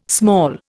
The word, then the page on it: small: Indian